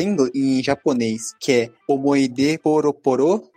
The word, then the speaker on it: Omoide Poroporo: Omoide Poroporo.